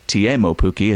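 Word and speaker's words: Teemu Pukki: He has scored, Timo Pukki, or Timo Pukki, as he's told us it's now pronounced, he has scored in each of his first three Premier League games.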